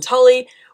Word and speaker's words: Tully: All of my handles are just my name at Jaylin Tully,